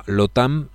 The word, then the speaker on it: LOTAN: Y de Most Generator a LOTAM, así como se escribe, como LATAM, como la línea aérea, pero cambiando las letras. En lugar de LATAM, LOTAM.